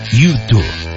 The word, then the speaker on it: U2: ya que se pronuncia igual a tú también en español, U2.